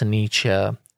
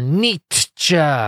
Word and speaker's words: Nietzsche: That's just how I've always pronounced it until someone very recently corrected me that it's Nietzsche. Or Nietzsche, if you're one of the real aggressive types.